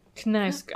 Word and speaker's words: Knausgård: Nauskard? – We decided it's Karl Ove Naus- Knausgård.